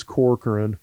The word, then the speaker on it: Corcoran: They really only got one really key guy out of this recruiting class, and that was Corcoran. I think I'm saying that right. – Yeah.